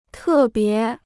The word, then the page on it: 特别: tè bié